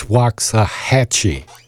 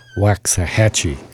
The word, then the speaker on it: Waxahachie: So also, I've been corrected on pronouncing, and I practice this. It's Waxahachie. Waxahachie.